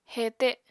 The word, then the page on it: hete: apatani